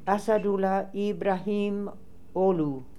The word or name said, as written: Assadulla Ibrahim Ogly